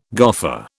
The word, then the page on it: goffer: British English